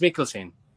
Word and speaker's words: Mikkelsen: Max Mikkelsen.